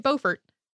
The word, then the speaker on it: Beaufort: Probably you say it Beaufort. Please do not write to us. you say that we said it wrong. In South Carolina, for some reason, it is Beaufort, even though most of these places that most other people say Beaufort are named after the same person who pronounced it Beaufort.